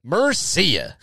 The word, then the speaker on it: Mercia: Mercia, which has its own entire, like, it's covered by a giant manufactorum, essentially. – Say it right. – Mercia. – Thank you.